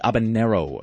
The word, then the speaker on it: habanero: And somebody told me that there's no tilde over the end, so I've been mispronouncing it for not just uh, like a few weeks, but actually for 36 years. It is actually habanero, apparently.